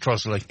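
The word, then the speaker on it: Trottiscliffe: It's Trosley. Everyone knows it's pronounced Trosley.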